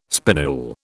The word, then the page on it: spinule: British English